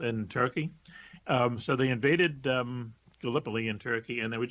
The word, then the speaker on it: Gallipoli: And what it was is it's actually, I think, pronounced Gallipoli. It's a place in Turkey.